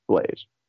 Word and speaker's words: Bloes: Okay, well, my last name is spelled like Blows, B-L-O-E-S, Curtis Blaze. You can find me at Linktree/Curtis Blaze, and it's got all my stuff there, all my photography stuff, all my podcasts, all everything.